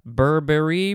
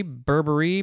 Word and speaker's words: Burberry: Uh, Burberry, Burberry, Burberry, a few different ways to pronounce it.